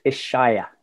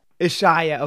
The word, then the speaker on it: Ishaya: Ishya monk. Did I pronounce that right? Almost Ishaya. Ishaya.